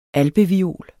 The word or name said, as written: alpeviol